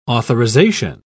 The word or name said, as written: authorization